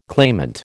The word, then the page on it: claimant: American English